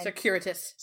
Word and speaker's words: circuitous: Because I apparently can't pronounce that word tonight. – So. Secure? – It's fine. – Circuitous. – Circuitous. – Circuitous.